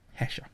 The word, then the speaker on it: Hesher: How do you spell that? – H-E-S-H-E-R. Hesha.